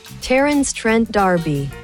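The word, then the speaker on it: Terence Trent D'Arby: Riktig uttale har jeg funnet fram. Du klar? – Jeg får høre. – Riktig uttale Terence Trent Darby.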